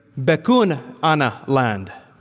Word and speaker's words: Bechuanaland: Back then it was called, let me say this correctly, Bechuanaland.